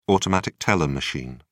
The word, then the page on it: automatic teller machine: British English